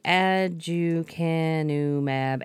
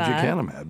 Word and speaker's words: aducanumab: In this first in-human proof-of-concept study, three patients with mild Alzheimer's disease received six standard monthly infusions of the aducanumab antibody, immediately followed by aducanumab.